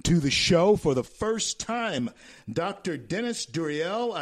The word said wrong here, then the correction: Deruelle: And is that the correct pronunciation of your name? – It's Deruelle. – Deruelle. – It's Deruelle. You were trying to make it a little French. It's just Deruelle.